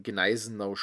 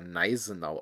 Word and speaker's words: Gneisenau: Gerade in englischsprachigen Kreisen gibt es manchmal die These, dass das G in Gneisenau stumm ist und nicht ausgesprochen wird und der Name daher Neisenau ausgesprochen werden würde. Selbst in Debatten mit Deutschen wird manchmal verbissen an dieser These festgehalten. Dabei gibt es abgesehen von ein paar Hs eigentlich keine stummen Konsonanten in der deutschen Sprache, wie zum Beispiel in Thron, und am Wort Anfang eigentlich so gut wie gar nicht.